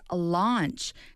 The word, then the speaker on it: launch: To be confused with the pronunciation lunch. – The thing you eat lunch. – 그죠? 점심은 lunch. Launch는 좀 더 이렇게 입을 좀 더 확장시켜서 맞습니다. – 발음해 줍니다.